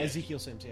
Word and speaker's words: Ezekiel Sims: It would have been Ezekiel Sims, right? Oh, did I say that right? – Oh, yeah, Ezekiel Sims, yeah.